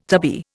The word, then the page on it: tubby: Indian